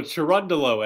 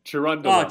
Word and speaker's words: Cherundolo: Oh, Cherundolo. Cherundolo. – Oh, Cherundolo. Yeah.